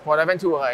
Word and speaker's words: PortAventura: And I know that we were going to ask you a little bit about our upcoming European event, Port Aventura. Did I say it right? – Yes, Port Aventura in Spain.